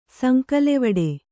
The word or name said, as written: ಸಂಕಲೆವಡೆ